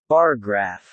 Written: bar graph

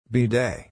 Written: bidet

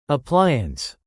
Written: appliance

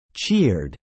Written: cheered

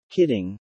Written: kidding

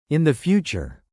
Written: in the future

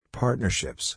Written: partnerships

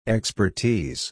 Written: expertise

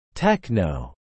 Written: techno

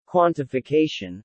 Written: quantification

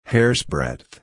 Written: hairsbreadth

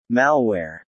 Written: Malware

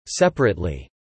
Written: separately

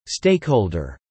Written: stakeholder

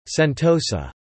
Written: Sentosa

